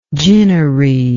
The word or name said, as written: ginnery